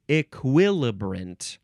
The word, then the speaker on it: equilibrant: Okay, the last word is equilibrant. Equilibrant, you can also say E, still emphasizing the second syllable, equilibrant. You can also say equilibrant. Equilibrant, spelled E-Q-U-I-L-I-B-R-A-N-T.